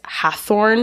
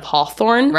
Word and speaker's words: Hawthorne: I think a lot of people mispronounce it as Hathorne mm-hmm. instead of Hawthorne.